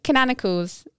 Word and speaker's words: canonicals: Did I say it wrong? Yeah. Canonicals. – Canonicals. – You know what? – Think of a canon.